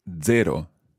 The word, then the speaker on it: zero: Zero in Italian is very straightforward. It's zero. – Zero. – Zero. – Zero. – It's like a DZ sound at the beginning. Zero.